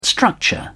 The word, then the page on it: structure: British English